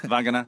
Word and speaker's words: Vagana: Vagana.